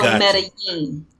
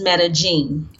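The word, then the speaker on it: Medellín: So, when you're in Latin America or Central America, the two L's are pronounced as a Y. So Medellin. – Medellin. – But when you are in South America, the two L's sound like a J. So it becomes Medellin.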